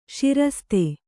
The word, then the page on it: ಸರಸತಿ: sarasati